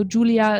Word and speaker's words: Julia: So Julia is clear, J-U-L-I-A. And then my last name is Arndt, A-R-N-D-T, and that's how you can find me on social media.